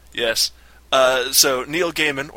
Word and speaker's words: Gaiman: I think it's Gaiman. – Okay, so Neil Gaiman. Gay man.